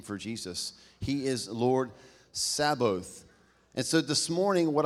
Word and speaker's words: Sabaoth: It's not a mispronunciation of Sabbath. It's S-A-B-A-O-T-H. It's pronounced Sabaoth, and it means Lord of hosts.